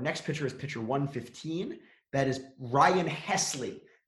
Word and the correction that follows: Helsley: Helsley? – Am I saying his name wrong? – I think there's another L.